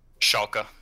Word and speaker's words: Schalke: So yeah, so the official teams that are going to be in it are Fnatic, Scalco Four. Is that how you say it, right? Schalke. Schalke.